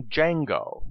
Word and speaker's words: Django: In the name Django, the DJ is pronounced with a J sound. "What's your name?" asks the stranger in the white hat and purple ascot. "Django. The D is silent," he answered. The problem is that the D in Django is not really silent. It is just not from English. Jakarta, Djibouti, and other names also use the digraph DJ to write the English J sound.